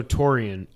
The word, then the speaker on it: Tourian: So Torian, I didn't know anything about, am I saying that right? T-O-U-R-I-A-N.